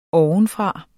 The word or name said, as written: ovenfra